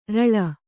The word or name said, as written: ಱಳ